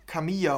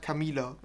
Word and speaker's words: Camila: müsstest du die Camilla aussprechen. Wie ein J. Die wird aber nur mit einem L geschrieben, deshalb Camila.